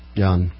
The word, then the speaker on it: yuan: Yuan. – Okay. It's 10,000 yuan, not yen.